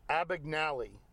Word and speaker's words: Abagnale: Ab- that was Abagnale. – Abagnale.